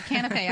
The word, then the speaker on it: canapé: Well, chef, let's talk about this gorgeous canapé platter. Canapé platter that you put out for us. Canapé. I saw it in my head, but I didn't pronounce it right.